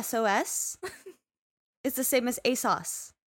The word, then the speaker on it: ASOS: Since I mentioned it just a moment ago, most of the clothes in Bat that you see are actually bought on like ASUS? I don't know if I'm saying that right. – ASOS? – ASUS. – ASUS. It's an O. Not a U.